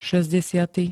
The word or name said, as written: šesťdesiaty